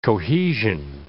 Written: cohesion

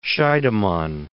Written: Scheidemann

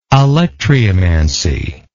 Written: alectryomancy

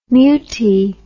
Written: mutie

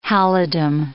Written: halidom

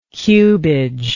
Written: cubage

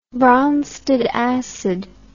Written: bronsted acid

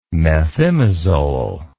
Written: methimazole

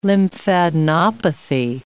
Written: lymphadenopathy

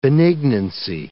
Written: benignancy

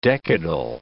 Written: decadal

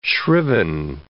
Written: shriven

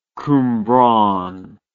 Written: Cwmbran

